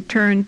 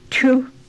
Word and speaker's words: tu: And tu, which is a very abrupt O, tu, means to go.